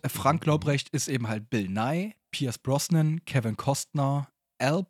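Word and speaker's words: Bill Nighy: Der N-I-G-H-Y geschrieben wird. Falls man sich fragt, wie es ausgesprochen wird, Bill Nye.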